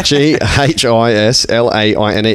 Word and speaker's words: Ghislaine: And she's got a silent H. Even her name's dodgy. G H I S L A I N E. She's got two silent letters.